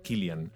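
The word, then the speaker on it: Cillian: Eh, bueno, que por lo que, por como lo pronunciaba, parece que se llama, se dice Killian, que nunca lo hubiera llamado así, pero bueno, es, cosas de los ingleses.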